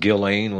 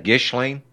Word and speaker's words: Ghislaine: Why is it pronounced Ghislaine when it's actually G-H-I-S-L-A-N. Isn't that Gishlane? French. Oui, oui.